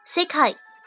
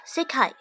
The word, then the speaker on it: 世界: 像我们来注意一下长音的发音吧，比如说 sekai 这个词是没有长音的， sekai 世界， sekai 如果把 se 发长音， sekai 就变成了正解，正确的答案 sekai。